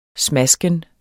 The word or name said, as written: smasken